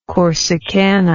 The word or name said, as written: corsicana